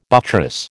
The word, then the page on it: buttress: American English